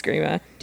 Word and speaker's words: Grima: Is it Grima? – Yeah. – It's Grima.